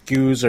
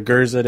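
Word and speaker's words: gueuze: I'm with you on that, but my favorite's actually Goose or Gerza, depending on how you want to pronounce it.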